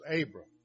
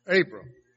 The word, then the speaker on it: Abram: And I've been pronouncing this Abram for a lot of years, but the way it was pronounced on the internet was Abram. So, Abram.